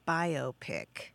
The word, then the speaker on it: biopic: Well, Kate, what we can tell you is that some people pronounce it the way you do, biopic, but the vast majority of folks say biopic. And that's because it is indeed a biographical film. It's a combination of the bio from biographical and pic is in picture.